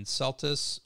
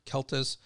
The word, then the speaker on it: Celtis: And then Celtis or Celtis, C-E-L-T-I-S, so it depends on how you pronounce that, are hackberries.